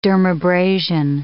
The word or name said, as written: dermabrasion